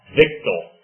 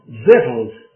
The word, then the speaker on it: victuals: And also, V-I-C-T-U-A-L-S. How do you pronounce that? – Victor. – No. Vittles. Of all things. People say, you are ignorant if you say vittles. Vittles is the way you pronounce V-I-C-T-U-A-L. And B-R-E-E-C-H-E-S is pronounced breeches.